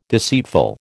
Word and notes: deceitful: American English

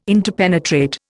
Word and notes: interpenetrate: Indian

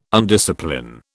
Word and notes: undiscipline: British English

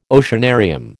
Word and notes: oceanarium: American English

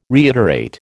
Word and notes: reiterate: American English

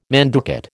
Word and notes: manducate: American English

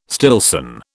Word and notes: Stillson: British English

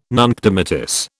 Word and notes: nunc dimittis: British English

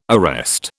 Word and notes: arrest: British English

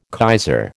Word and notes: kaiser: American English

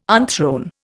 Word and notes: unthrone: Indian

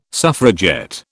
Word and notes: suffragette: British English

